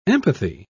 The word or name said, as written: empathy